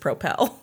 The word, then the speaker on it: Propel: I was delighted to learn that it's actually just Propel. – Propel. I thought you said Propel. – What's the difference? – Well, you put the emphasis on the wrong syllable.